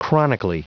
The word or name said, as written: chronically